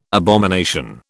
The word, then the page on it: abomination: British English